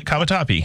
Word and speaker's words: Cavatappi: Am I saying it right? – Yeah, it is. I spelled it a little bit wrong, but yeah, no, you got it. Cavatappi.